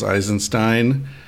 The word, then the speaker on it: Eisenstein: All right, ladies and gentlemen, I'm sitting over the waves in Big Sur, California with Charles Eisenstein. Pronounce it right? – Yeah, that's right.